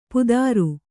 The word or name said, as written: ಪುದಾರು